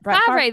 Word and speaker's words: Favre: Actually got three with that Brett Favre, or however you pronounce that. – Favre. That's what they say in Something About Mary.